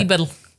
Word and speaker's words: Dieberl: Habe ich es richtig ausgesprochen? Ja. Dieberl. So, es ist Niederösterreicher L, musste.